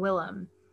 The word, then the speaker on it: Willem: And also Willem was his childhood nickname because his family is from Wisconsin and put emphasis on the first syllable and less so on the second syllable in terms of enunciation. So they would call him Willem.